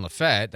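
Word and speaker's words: Lafayette: That when they went to help out in Lafette, that's how you say it, Lafette County, that they weren't just going to do a good deed.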